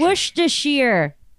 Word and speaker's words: Worcestershire: Do you, how do you say? – Worcestershire. – Worcestershire? Worcestershire. It's Worcestershire.